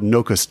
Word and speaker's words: Nokosu: which is a game called Nokus Dice. I believe I'm pronouncing that correctly. I believe that you do not say that U, but it is spelled Nokusu, if you were to spell it Nokusu, I believe.